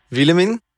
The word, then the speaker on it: Willemien: Wilhelmin, ganz herzlich willkommen bei uns hier in Rapperswil. Dankeschön. Habe ich den Namen richtig ausgesprochen? – Ja, das ist korrekt. Also auf Schweizerdeutsch, auf Holländisch betonen wir es anders.